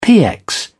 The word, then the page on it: px: British English